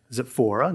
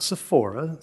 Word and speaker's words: Zipporah: And we see that he marries one of his daughters, Zipporah, not Sephora, Zipporah, Zipporah, however you want to pronounce it.